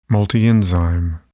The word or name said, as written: multienzyme